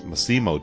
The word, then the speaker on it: Massimo: I hope I got his name right. I didn't even have it sitting in front of me. Yes, Massimo Dallamano. So I think I threw an extra syllable in there.